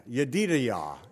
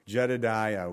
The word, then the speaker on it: Yedidiah: Yedidiah is how they say it. Jedidiah, we look at it and we, in our American, Yedidiah, meaning beloved of the Lord.